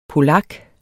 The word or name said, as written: polak